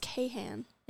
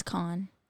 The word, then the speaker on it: Kahan: I thought it was Kahan. – It's Kahan. – Really? – Yeah. It's spelled Kahan, but it's pronounced Kahan.